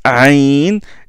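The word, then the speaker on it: aïn (ع): Le aïn, ça se prononce aïn, et juste après, hacha, hein, et c'est le ha.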